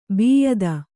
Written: ಬಿಯದಿ

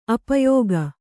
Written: ಅಪಯೋಗ

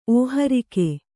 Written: ಓಹರಿಕೆ

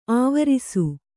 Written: ಆವರಿಸು

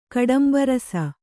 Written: ಕಡಂಬರಸ

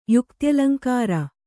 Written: ಯುಕ್ತ್ಯಲಂಕಾರ

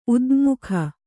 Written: ಉದಙ್ಮುಖ

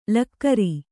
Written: ಳಕಾರ

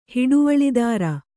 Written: ಹಿಡುವಳಿದಾರ

